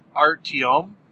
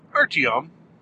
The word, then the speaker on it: Artyom: Just because there's a lot of backstory involving the main character who you play by the name of Artyom. Artyom. A-R-T-Y-O-M is his name. How you pronounce it is helpful if you have a Russian accent.